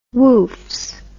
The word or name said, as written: woofs